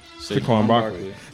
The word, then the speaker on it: Saquon Barkley: We all know that the Giants are going to select Saquon Barkley. Did I say his name right? – Saquon Barkley.